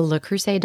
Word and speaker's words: Le Creuset: A Le Creuset Dutch oven. – I feel like you pronounced that really well.